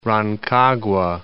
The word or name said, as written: Rancagua